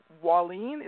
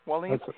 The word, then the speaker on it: Wallen: So we did Carl Seffeller, Hans, and then Hans Axel Wallin. Is that how you say it? W-A-L-L-E-N? – That's right, yeah. – Wallin? – Wallin, yeah.